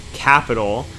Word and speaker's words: Qapital: And then he shared an app, which is called Capital. So that's just pronounced like the word capital, but with a Q. So Q-A-P-I-T-A-L.